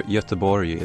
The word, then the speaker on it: Göteborg: Gothenburg, or Göteborg is the Swedish pronunciation of it.